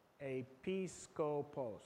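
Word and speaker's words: Episcopos (ἐπίσκοπος): It gives us, oh, I see, I even pronounced it wrong, because it gives us the pronunciation. Episcopos.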